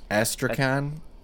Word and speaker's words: Astrakhan: Astrakhan.